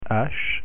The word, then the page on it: h: French